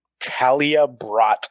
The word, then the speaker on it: Talia Brott: Talia Brott. – Imagine if you got that wrong. – How do you spell that? – T-A-L-I-A.